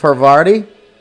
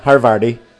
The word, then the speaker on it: Havarti: And a piece of Havarti. Did I say that wrong? Havarti, not Harvardi. Havarti! And a tomato slice.